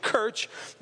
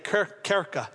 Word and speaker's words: Kirche: That word church is not the German word in the 15th century, which we phonetically look at it and it's pronounced in our language, Kirch. But Richard, Pastor Richard, has been to Germany several times and he came to me and said, that's not how they say it in German. They say it like Kirche.